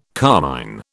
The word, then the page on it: carmine: British English